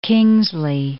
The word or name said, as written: Kingsley